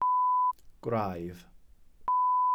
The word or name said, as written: gwraidd